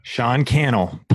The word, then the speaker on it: Sean Cannell: Sean Cannell.